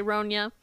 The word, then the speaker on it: Ronja: It's spelled like Sonja with the sexy J, but it's with an R, so I'm going to say Ronja.